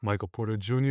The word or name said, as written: Michael Porter Jr.